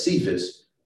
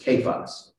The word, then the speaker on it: Cephas: And as we said, some people refer to Peter as Cephas, but the pronunciation is Kephas.